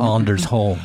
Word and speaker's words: Anders Holm: Anders Holm.